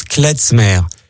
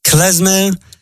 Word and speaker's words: klezmer: Et il faut aussi, d'ailleurs, préciser, j'entends parfois des gens qui prononcent klezmer parce qu'ils assimilent le Z au Z allemand. Or, bien évidemment, c'est de l'hébreu, zemer.